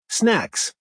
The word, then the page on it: snacks: American English